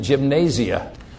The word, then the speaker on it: gymnasia: The Greek word is gymnasia. G-Y-M-N, A-S-I-A. We would pronounce it gymnasia. That's how it's pronounced, gymnasia.